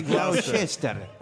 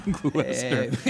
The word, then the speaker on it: Gloucester: Is it pronounced a Gloucester or a Gloucester? – I think it's Gloucester. – It's spelled Gloucester, but they pronounce Gloucester, just like a Worcester.